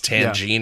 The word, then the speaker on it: Tangina: Is that how you say her name? – Yeah, it's Tangina. Yeah.